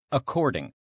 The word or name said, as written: according